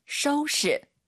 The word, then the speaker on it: 收拾: 收拾. – 한번 더. – 收拾. – 지금 오유 발음이 연달아 두번 있죠? 떠우 셔우예요. 절대 또쇼 아닙니다, 여러분.